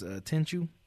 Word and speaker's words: Tenchu: Tenchu.